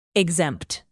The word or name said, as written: exempt